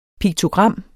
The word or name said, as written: piktogram